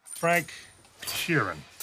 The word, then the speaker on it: Frank Sheeran: Frank Sheeran. I saying that right? Yeah, you said right.